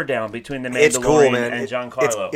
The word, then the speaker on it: Giancarlo: Giancarlo, yeah, that was it. I said it right. It was nice.